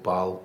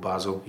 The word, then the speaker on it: Basel: I remember Saturday afternoon, and the team we were playing used to be called Baal, they're now called Basel, even though it's spelled exactly the same way, but that's what they're called now.